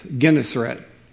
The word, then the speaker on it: Gennesaret: I had to get on and have him pronounce it on the web, and now I forgot. It's Gennesaret is what it is, but it really is the Sea of Galilee.